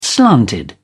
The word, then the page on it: slanted: British English